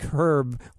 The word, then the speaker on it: herb: Not like, it's kind of like herb, where we pronounce it herb in America, and but it's herb in other countries, you know, it's H-E-R-B.